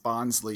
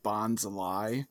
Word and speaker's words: Bonsly: We have, you know, if you pronounce it Bonsly, you don't really get the pun, but Bonsly, we have the lie.